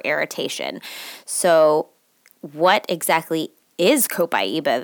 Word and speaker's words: Copaiba: Copaiba is how you pronounce it, and it is even more awesome to use.